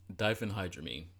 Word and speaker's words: diphenhydramine: Diphenhydramine.